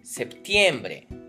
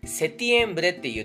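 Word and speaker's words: septiembre: 9月セプティエンブレ。この9 月のセプティエンブレというのは、ちなみにですけども、この P のプーという発音がですね、落っこっちゃってて、セティエンブレって言っている人、または国地域もありますけれども、正しくはセプティエンブレなので、一応ここではプーを入れて覚えた方がいいと思います。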